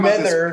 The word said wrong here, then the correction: Mayweather: Well, for anyways, Floyd Mayweather.